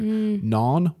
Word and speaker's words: gnawn: So the one who's gnawed with me, gnawed with me, it's a bit of a tongue twister, gnawn with me, silent G.